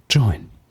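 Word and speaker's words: Joyn: Melissa macht wieder hier kurz, wie hast du Talk. Join gerade ausgesprochen? – Join. – Genau, okay, jetzt bin ich wieder dran.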